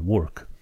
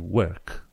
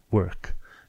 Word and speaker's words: work: Și chiar mi-a povestit dacă vrei să spui că te duci la work, în mod normal ești învățat cu accentul american, work. Dar i-a spus, mă, vezi că britanicii nu pronunță work, imaginează că cuvântul ăsta s-ar scrie W-R-K și atunci îl pronunți ca și cum l-ai citit work, work.